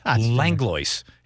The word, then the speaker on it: Langlois: I said it's, it's Langlois.